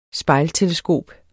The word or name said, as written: spejlteleskop